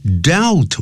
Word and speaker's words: dealt: His comic strip，他的这个连环漫画，dealt with deals 啊，D E A L，如果是现在时第三人称单数还加个 s，那么过去时就要念成 dealt with psychology，探讨的是心理学。